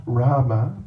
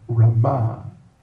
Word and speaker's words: Rama: Not Rama, but Rama. The emphasis on the last syllable.